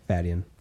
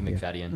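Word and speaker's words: Macfadyen: How do you say his name? – Fadden. – Fadden. Yeah. Matthew McFadden.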